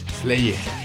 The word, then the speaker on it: Slayer: Slayer. – Slayer. – Su mejor pronunciación.